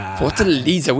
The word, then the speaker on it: Fortaleza: Fortaleza.